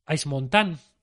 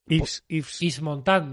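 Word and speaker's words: Yves Montand: que protagoniza Ice Montan. Ives Montan, depende de si lo quieres pronunciar bien o mal, John, depende un poco.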